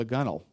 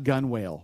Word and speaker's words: gunwale: You may be in a raging storm, But somehow, with water coming over the gunwale, did I say that right? It's not gunwale like it looks, okay?